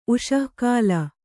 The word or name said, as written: ಉಷಃಕಾಲ